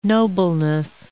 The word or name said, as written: nobleness